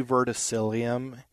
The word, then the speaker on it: verticillium: I think she called it verrillum or verrillium. I'm not saying it right. – Probably verticillium. – Thank you. That's it.